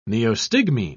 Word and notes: neostigmine: British English

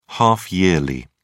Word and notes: half-yearly: British English

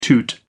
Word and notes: toot: American English

